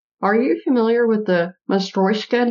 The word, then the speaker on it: Matryoshka: And she introduced us to the Russian nesting doll, and she pronounced it Matryoshka.